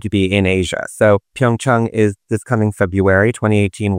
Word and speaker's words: Pyeongchang: I haven't been to Pyeongchang yet. And I only say it Pyeongchang because we're being told that's the proper way to say it. But I also think it's Pyeongchang.